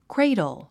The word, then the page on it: cradle: kréidl; クレイドル